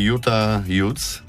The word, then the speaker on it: Utah Utes: I Utah Utes, dobrze wymawiam? – Tak.